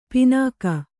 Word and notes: ಪಣ್ಣಿಕೆ: paṇṇike

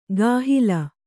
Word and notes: ಗಾಹಿಲ: gāhila

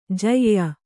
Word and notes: ಜ್ಯಾ: kannada

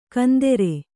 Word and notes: ಕಂದೆರೆ: kannada

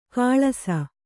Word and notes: ಕಳಸ: Kannada

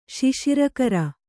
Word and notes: ಶಿಶಿರಕರ: Kannada

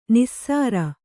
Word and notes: ನಾಸೀರ: nāsīra

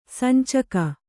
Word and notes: ಸಂಚಿಗೆ: sancige

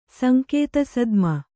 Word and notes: ಸಂಕೇತಸದ್ಮ: Kannada

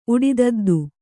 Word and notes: ಉಡಿದದ್ದು: Kannada

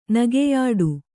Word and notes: ನಗೆಯಾಡು: nageyāḍu

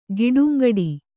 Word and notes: ಗಿಡುಂಗಡಿ: Kannada

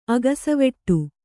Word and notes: ಅಗಸೆವಟ್ಟೆ: agasevaṭṭe

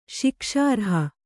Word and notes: ಶಿಕ್ಷಾರ್ಹ: śikṣārha

